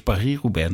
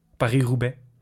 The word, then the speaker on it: Paris-Roubaix: Iemand verbeterde mij en zei het is Paris-Roubaix natuurlijk. Maar ik ben een gewone Nederlandse jongen gebleven. – De Franse uitspraak. Paris-Roubaix.